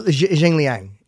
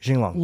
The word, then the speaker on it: Jing Long: it does look like Jing Liang. – All right. Yes. But it's not. How do you pronounce it? – Jing Long. – Long. It's Jing Long.